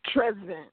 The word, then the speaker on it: Tresvant: Though her first attempt at a romance novel starring Ralph Tresvant? Is that how you say it? – Tresvant. Yeah, Tresvant. My Tresvant.